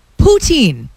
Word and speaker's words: poutine: I was out with a friend yesterday, and she pronounced poutine poutin. – Where's she from? – She's from Ontario originally. – Yeah. – Is that it? But that's, I don't do that. I looked at her and I was like, what? – I've only ever heard two people pronounce it like that, and they were both from Ottawa.